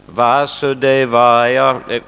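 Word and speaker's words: Васудевая (वासुदेवाय): Вы говорите Васудевая. А? Да, потому что я слышал, что в русских словах ударение на одном слоге. Наверное, из-за этого вы так говорите Васудевая. Но если слушаете лекции Шрила Прабхупады, он произносит Васудевая. Это как будто ударение на двух слогах. Может быть трудно для людей, которые говорят по-русски.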